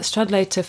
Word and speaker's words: Stradlater: Now, there's also a disgust at, is it Stradlater? I can never know how to say it. – Stradlater.